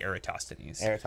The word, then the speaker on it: Eratosthenes: Eratosthenes. – I don't know. – Yeah, Greek. – In the audiobook Cosmos, they say Eratosthenes.